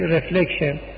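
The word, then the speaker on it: reflection: حتی خب تلفظ انگلیسیشون میشه چی؟ رفلکشن.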